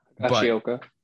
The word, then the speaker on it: Higashioka: Higashioka.